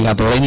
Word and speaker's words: Gatorade: Nada, ni Gatorade ni nada.